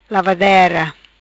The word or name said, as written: Lavadera